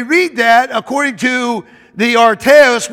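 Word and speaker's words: artios: I want you to understand now in the Greek, this word perfect comes from the Greek word arteos. It's spelled A-R-T-I-O-S. A-R-T-I-O-S.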